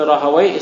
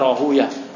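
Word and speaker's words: Rahawayh: Qala Ishaq ibn Rahawai, Ishaq ibn Rahawai menyatakan, ini ada ulama yang menyatakan membacanya Rahuya. Ini khilaf ya,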